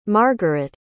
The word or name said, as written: margeret